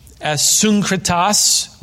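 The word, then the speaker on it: Asyncritus: Verse 14, greet Asuncritas, which is the Greek pronunciation.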